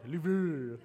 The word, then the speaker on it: Louvre: what, how do you say it? The Louvre? That's it? Okay, that's easy. I was trying to make it way too hard. Louvre. Uh, it's just the Lou.